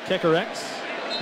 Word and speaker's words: Kekerix: Kekerix. Almost say every syllable.